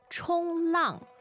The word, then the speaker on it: 冲浪: 冲浪. 冲浪. – 冲浪. Mm. That sounds like a first tone and a fourth tone. Is that correct? – You are right. – Good. Do you actually 冲浪?